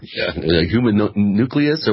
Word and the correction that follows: homunculus: Human nucleus? How do you say it? – A homunculus, right. – Homunculus, yeah. There we go.